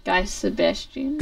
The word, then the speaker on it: Guy Sebastian: Wait, how do you say his name? – Guy Sebastian. – Guy Sebastian. – She says Guy Sebastian.